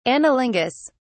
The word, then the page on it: anilingus: British English